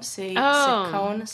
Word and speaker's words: Ciccone: Where does Madonna stand in the, how do you say her last name? Ciccone? – Ciccone. – Ciccone. Oh, I was way off.